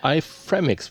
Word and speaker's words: iFramix: iFramix würde ich sagen. – iFramix, ja. So würde ich es auch aussprechen. – So wie Euronix.